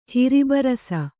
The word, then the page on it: ಹಿರಿಬರಸ: kannada